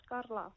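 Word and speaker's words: Karla: Because I was going to go Carla, but then I saw the K and I'm like, this has to be Carla. – Yeah, it's Carla.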